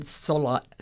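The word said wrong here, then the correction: Szalay: David Soloy, but that's not phonetically how it's spelled. So it's S-Z-A-L-A-Y.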